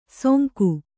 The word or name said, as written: ಸೋಂಗು